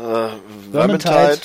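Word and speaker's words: Vermintide: Worm, wie, wie spricht man das aus? Ich kann's. – Äh, Wormentide, äh, sag ich, will ich jetzt mal so sagen, das könnte ich frei übersetzen mit Ungezieferplage.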